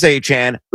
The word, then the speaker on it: Achane: Again, it is pronounced Travis Achane. Look it up, folks, if you don't agree. It was straight from his mouth, not mine or Pat's.